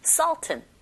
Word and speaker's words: sultan: 네, 우리는 술탄이라고 읽지만 발음은 sultan 이렇게 하시면 돼요.